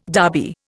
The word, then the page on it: Derby: Indian